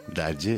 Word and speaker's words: daddy: Дади или Дэдди, Дади, да? – Они, знаете, шотландцы произносят вот «э», у них почти как «а», очень открыто. – Дади, своеобразный, да.